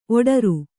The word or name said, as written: ಓಡ್ರ